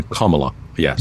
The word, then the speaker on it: Kamala: Like, I just now, in my mind, I think of a comma. – Comma. – And then Kamala. Yes.